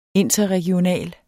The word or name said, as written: INTERREGIONALE